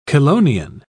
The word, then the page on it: chelonian: British English